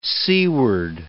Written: seaward

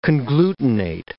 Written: conglutinate

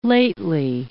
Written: lately

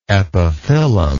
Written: epiphyllum

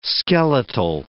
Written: skeletal

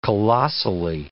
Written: colossally

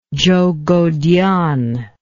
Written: zhoukoudian

